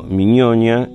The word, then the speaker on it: Mignogna: Está, si ponen Eduardo Miñoña, que se escribe M-I-G-N-O-G-N-A, Mignogna, Horacio Quiroga, lo van a encontrar.